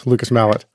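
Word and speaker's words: Lucas Malet: Before we move on to Dickens, can you tell us a little bit about Lucas Mallet? Am I pronouncing the Mallet? – Lucas Mallet.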